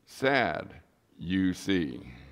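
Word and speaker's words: Sadducees: And that's why you call them sad, you see. What? That's how you pronounce it. It's not a hard Greek word.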